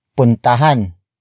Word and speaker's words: puntahan: Another example, puntahan mo ang ospital. Puntahan mo ang ospital. Puntahan mo ang ospital. It means go to the hospital. The focus is on the hospital. Word for word it's puntahan mo ang ospital.